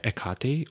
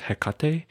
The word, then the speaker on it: Hecate: Recently, I've been finding myself for the past few months really inspired by Hecate, or also, you know, pronounced Hecate sometimes.